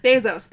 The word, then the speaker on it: Bezos: Jeff and Mackenzie ben- Benzos? Is that how you pronounce it? – Bezos. – Bezos.